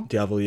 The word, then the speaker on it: Diavel: Is it the Diavel? – Diavel, yeah.